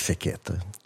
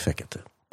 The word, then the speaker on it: Fekete: Eh, altså i ungarsk så er det alltid trykk på første stavelsen. – Ja. – Men det er klart at jeg har tilpasset litt grann, uh, uttalen siden jeg uh, bor og lever her. Ja. Så jeg bruker fekkete. – Uh, eh, i, I, Ungarn ville jeg sagt fekkete.